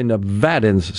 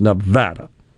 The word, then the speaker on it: Nevada: By the way, Nevadans say that is the way you pronounce Nevada, not Nevada, which I do a lot. But when you get there, they'll say, it's Nevada.